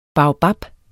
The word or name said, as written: baobab